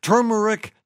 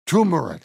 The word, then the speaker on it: turmeric: Turmeric has two R's and is pronounced turmeric, not turmeric.